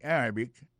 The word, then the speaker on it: Arabic: Mohammed speak Arabic and English. Mohammed parle arabe avec anglais. Mohammed speak Arabic and English. Arabic, là, c'est comme ça, on dit arabe en anglais. Arabic. A-R-A-B-I-C.